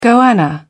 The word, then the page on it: goanna: British English